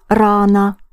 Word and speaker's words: рано: The word for early is рано. Рано. Think of run. Yes, you have to run to arrive early.